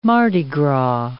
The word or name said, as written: Mardi Gras